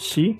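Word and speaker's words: Shi (四): Por cómo se pronuncia, ¿no? Exacto. Shi, suena como muerte.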